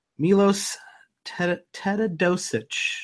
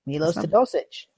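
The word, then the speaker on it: Miloš Teodosić: And they ended up signing, I'm going to butcher this one, Milos Teodosic. – Milos Teodosic. You almost got it.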